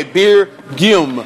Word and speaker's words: Beergium: B-E-E-R-G-I-U-M. – G-I-U-M. Like Belgium. Okay, Beer Gum. – Yes. – Like Beer Belgium, but Beer Gum.